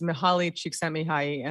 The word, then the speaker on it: Mihaly Csikszentmihalyi: Yes, and it's Mihaly Csikszentmihalyi, and I know that because I did the audiobook for my for this book, and dear God, did I have to say that name a lot of times and had to go do pickups for it.